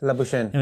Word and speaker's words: Labuschagne: Plus, he dropped a very simple catch as well of Labuschagne. I don't know how he's pronounced. – Labuschagne. – I mean, Labuschagne, yeah, but he's South African, so his name is different.